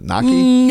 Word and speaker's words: gnocchi: Gnocchi? – Gnocchi.